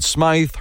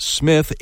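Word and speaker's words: Smyth: Many people pronounce it Smythe. Her family actually preferred Smith.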